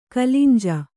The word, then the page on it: ಕಲಂಜ: Kannada